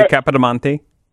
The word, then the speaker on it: Capodimonte: they're Capodimonte. – Sure. Yeah, you said that correctly, Capodimonte.